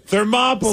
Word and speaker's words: Thermopylae: Thermopylae. – So it rhymes with Monopoly.